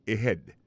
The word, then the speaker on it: ahead: Et head là c'est AHEAD. Ahead. Ahead. Go ahead. Là encore, on a vu que l'accent tonique là tombé sur deuxième syllabe. Là, son mot gagne deux syllabes.